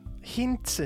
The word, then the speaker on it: Hintze: Hinse, jeg ved ikke, om jeg udtaler det rigtigt. Skal vi se. Øh, han øh, spiller Hubert. Hinse. Hint, Hintze, det er sådan Hintze. tysk.